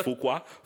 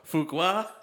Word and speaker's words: Fuqua: Fuqua. – Fuqua? – Yeah.